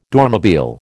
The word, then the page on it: Dormobile: American English